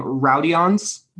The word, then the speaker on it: Rodions: You say his name, Rodions.